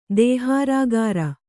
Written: ದೇಹಾರಾಗಾರ

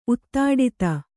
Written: ಉತ್ತಾಡಿತ

